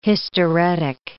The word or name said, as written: hysteretic